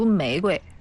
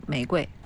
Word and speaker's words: 玫瑰: 读玫瑰，它不带，也不是一声，也不是四声，它就是无声，轻声，玫瑰。